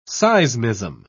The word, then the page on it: seismism: British English